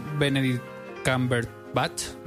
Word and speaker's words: Benedict Cumberbatch: Dicen actores como Benedict Cumberbatch, ¿lo he dicho bien?